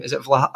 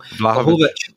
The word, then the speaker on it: Vlahovic: The only one who's probably left is it Vlahovic? I always get his name wrong. – Vlahovic.